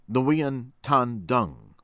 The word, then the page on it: Nguyen Tan Dung: American English; from Vietnam